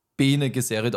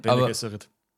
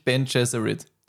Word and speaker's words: Bene Gesserit: Im Film wird sie Bene Gesserit ausgesprochen. – Bene Gesserit. – Wieso Klugscheiße? – Ich sage ja. Ja. – Aber ich weiß nicht warum. Wegen den ganzen YouTube-Videos, die ich angeschaut habe, sage ich Ben Gesserit.